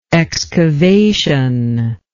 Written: excavationist

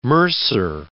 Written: mercer

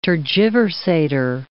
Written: tergiversator

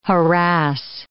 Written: harass

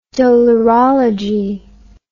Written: dolorology

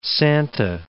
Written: Santa Catalina

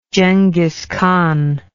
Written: Jenghis Khan

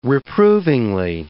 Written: reprovingly

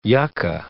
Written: yucca

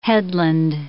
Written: headland

